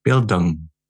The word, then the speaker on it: Bildung: Bildung.